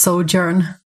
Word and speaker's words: Sojourn: Eh, jag tror att det mm. uttalas Sojourn. – Ja. S-O-J-O-U-R-N. Mm, precis.